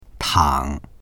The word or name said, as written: tang3